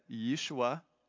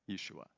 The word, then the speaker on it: Yeshua: And then Yeshua is how you say that in Aramaic, I believe. Yeshua.